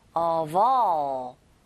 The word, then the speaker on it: of: Of의 V, 철자는 F지만 이게 소리가 V, 소리가 나거든요.